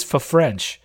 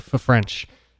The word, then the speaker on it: Ffrench: Yes, his last name is F-F-R-E-N-C-H. – I thought it was a misspelling on Football Study Hall, so I went to ESPN, and it was spelled the same way, and then I realized it's actually Fafrench. – Yeah, and it's Fafrench. I don't know. I guess it's probably just French when you pronounce it, but it's spelled Fafrench. So yeah, it's got 2 Fs like at the beginning.